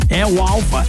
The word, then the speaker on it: El Alfa: Não, El Alfa é o correto, mas eu falei outro nome.